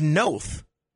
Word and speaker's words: Noth: It's Noth.